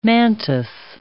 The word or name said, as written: mantis